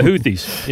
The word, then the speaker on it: Houthis: And the do you pronounce them the Houthis? Is that Houthi? – Yeah. It's not the blowfish. It's the Houthis.